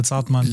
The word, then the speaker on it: Zartmann: Die Betonung auf Mann bei Zartmann.